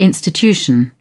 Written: institution

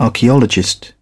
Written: archaeologist

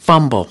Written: fumble

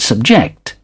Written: subject

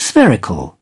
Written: spherical

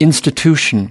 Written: institution

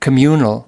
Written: communal

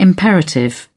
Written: imperative

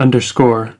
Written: underscore

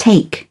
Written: take something up

